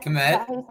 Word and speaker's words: Kmet: Kmet. – Yeah, there we go. Kmet, yeah.